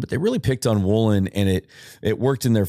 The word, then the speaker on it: Woolen: I'm sorry, Reek Woolen, apparently, according to Al Michaels. He wants it to be pronounced Woolen.